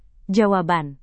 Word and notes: jawaban: Indonesian

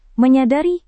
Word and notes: menyadari: Indonesian

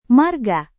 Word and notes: marga: Indonesian

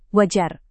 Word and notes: wajar: Indonesian

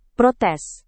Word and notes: protes: Indonesian

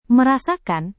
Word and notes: merasakan: Indonesian

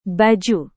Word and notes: baju: indonesian

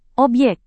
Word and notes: obyek: Indonesian

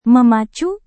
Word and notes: memacu: Indonesian

